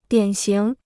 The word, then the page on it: 典型: diǎn xíng